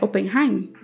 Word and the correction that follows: Oppenheimer: Acho que é, né? – Oppenheimer. – Isso.